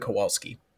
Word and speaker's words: Kowalski: Brian Haley as Mitch Kowalski, Brian Howe as Steve Kowalski, and Geraldine Hughes as Karen Kowalski. I should ask, Karen, how's my pronunciations on those? – I think you nailed it, to be completely honest with you.